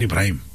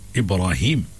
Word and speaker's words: Ibrahim: It's not Ibrahim. Ibrahim. – True, yeah. – I see, it's good now. Mothers are very particular when they call Ibrahim.